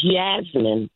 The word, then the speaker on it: Yasmin: No. It's Yasmin, Yasmin, I'm sorry. – Y-A-S-M-I-N, Grand Rising.